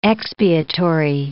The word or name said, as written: expiatory